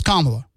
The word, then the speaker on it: Kamala: So you've heard her name pronounced as Kamala Harris many, many, many, many, many times. So if you're still pronouncing it wrong, it's because you're either ignorant or you're doing it intentionally. So it's Kamala.